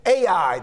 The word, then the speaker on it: Ai: Now, that's the way I'm going to pronounce it. I've heard it pronounced different ways I or IE. I'm going to say AI mainly because that's the way I learned it when I was a little boy.